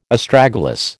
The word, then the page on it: Astragalus: American English